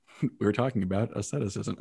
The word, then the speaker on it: asceticism: It is from asceticism. So you were close. You had the first syllable right. But asceticism, which means practice and exercise.